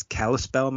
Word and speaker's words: Kalispell: is Kalispell, Montana. Mm-hmm. I could be pronouncing that wrong. – No, that's correct. – Is it? Okay. – Yeah, you did it.